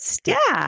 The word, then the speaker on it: Stille: I wasn't pronouncing the first part correctly. But Stille.